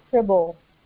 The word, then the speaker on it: Kribble: It's actually Kribble, the German last name, but you can pronounce it any way you want.